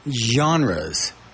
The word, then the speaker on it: genres: It's pronounced with a Z, genres. So different genres. When in the English language is a G pronounced like a Z? I've never heard that before, but it does say genres.